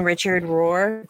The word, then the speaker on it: Richard Rohr: And she touched that here, like in that next couple paragraphs, her, there's that Jin Richard Rohr. That's how you pronounce his name.